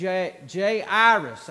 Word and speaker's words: Jairus: And tonight we're just going to deal with this man named Jairus. That's how you say his name. And the reason I know that's how you say his name is because that's how Alexander Scorby says his name.